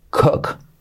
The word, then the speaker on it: cook: Y las dos us como os, ¿vale? No se dice he's a good cook. He's a good cook. He's a good cook. Very good. No. He's a good cook. Una U casi como una O.